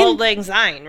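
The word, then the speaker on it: Auld Lang Syne: I want to learn the old Lang Zai song. Don't know it. I feel like that should be something. – First of all, you should just learn how to say it. – That's not... I didn't say it right? – No. – Don't keep me... – Old Lang Syne, right? – Isn't that how you say it? – What did you say?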